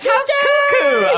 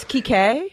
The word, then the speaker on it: Kike: And so my mom's always like, Cuckoo. – I'm like, Ma. – It's Kike. – Kike. – Mm-hmm. I've been saying Kiki.